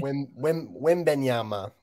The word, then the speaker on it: Wembanyama: I guess outside the box, the Spurs got won the draft lottery tonight, getting Victor Wembanyama. Did I say that right? – Wembanyama. Yeah, you did.